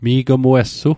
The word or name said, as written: mi'gmuessu